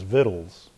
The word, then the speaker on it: victuals: it looks funny, but the way to pronounce it is victuals.